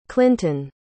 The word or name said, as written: clinton